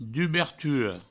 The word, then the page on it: dubertura: duberturo; Provençal